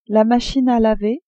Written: machine a laver

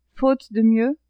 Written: faute de mieux

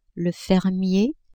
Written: fermier

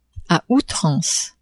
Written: a outrance

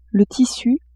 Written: tissu

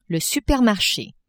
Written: supermarche

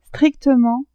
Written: strictement

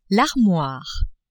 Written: armoire

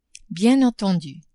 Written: bien entendu

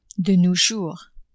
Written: de nos jours

